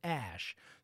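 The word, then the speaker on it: Æ: The billionaire later said that the symbol was pronounced as ash, saying it was pronounced this way, X-A-12.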